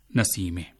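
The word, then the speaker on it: نسیمه: سامعین, آپ نے یہاں جو جملے سنے ان میں خواتین کے جو نام ہیں رضیہ ناصرہ اور نسیمہ، ان کا فارسی تلفظ آپ نے ملاحظہ کیا کہ رضیہ کو رضیے کہا گیا، ناصرہ کو ناصرے کہا گیا اور نسیمہ کو نسیمے۔ ہم بتا چکے ہیں کہ یہ ہائے غیر ملفوظ کہلاتی ہے، اور اس طرح کے سبھی ناموں کو آپ اردو کی بڑی یہ کی طرح تلفظ کیجیے۔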